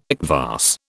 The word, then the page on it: kvass: British English